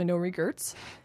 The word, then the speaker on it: No Regerts: That wasn't mispronounced. It's actually No Regerts.